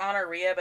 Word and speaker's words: Honoria: It looks like honoria, but that sounds like a STD. It's like honor IA. So I assume they would pronounce it Honoria, but it does look like honoria.